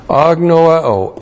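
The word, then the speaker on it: agnoeo (ἀγνοέω): And then we have agnoeo. Agnoeo. A-G-N-O-E long O.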